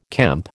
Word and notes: kemp: American English

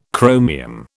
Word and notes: chromium: British English

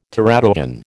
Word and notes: teratogen: American English

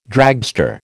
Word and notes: dragster: American English

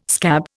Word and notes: scab: Indian

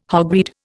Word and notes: hogweed: Indian

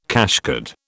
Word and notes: cashcard: British English

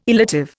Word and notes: illative: Indian

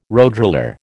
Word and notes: roadroller: American English

